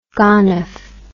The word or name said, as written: gonof